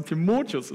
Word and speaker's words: Timotheus: Ich glaube, richtig ausgesprochen, wahrscheinlich werde ich auch mal immer Timotheus sagen, aber ich habe mir sagen lassen, Timotheus ist die richtige Betonung.